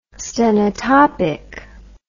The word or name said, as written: stenotopic